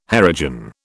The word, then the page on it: Harijan: British English